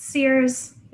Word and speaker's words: CIRS: Some people pronounce it Sears. It's the jury's still out on the pronunciation sometimes, depending on regional dialects.